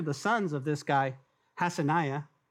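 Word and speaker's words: Hassenaah: Also, the hands of Satsani built the fish gate, and they laid its beams and hung its doors and its bolts and its bars. Hassaniah, I said that wrong first time, Hassaniah built the fish gate.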